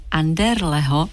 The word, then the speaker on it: Anderleho: Život Jiřího Anderleho, a ta výslovnost je správná, opravdu říkejte Anderleho, je naplněn kreslením, malováním, psaním, hudbou, rozmanitými podobami tvorby.